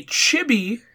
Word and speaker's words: chibi: Uh, they introduced a chibi, uh, chibi, chibi. How do you say it? C H I B I. – I think it's Chibi. – I think it's Chibi. Yeah.